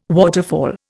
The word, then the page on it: waterfall: Indian